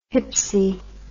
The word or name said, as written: hypsi